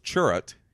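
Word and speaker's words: Chirrut: Churrut Imwe. Pronounced Churrut,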